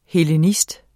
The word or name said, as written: hellenist